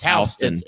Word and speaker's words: Houston: Well, in Warner Robins, the county is spelled Houston, but it's pronounced Houston. It's Houston.